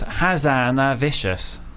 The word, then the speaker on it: Hazanavicius: It actually is pronounced Hazanavicius. Hazanavicius.